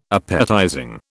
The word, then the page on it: appetizing: British English